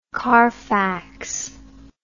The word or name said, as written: Carfax